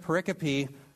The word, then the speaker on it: pericope: And by the way, I'm not mispronouncing periscope. Every time I write pericope, my little corrective engine that handles misspellings says periscope.